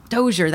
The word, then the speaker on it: Dozier: Dozier, that's how you say it.